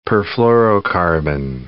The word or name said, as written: perfluorocarbon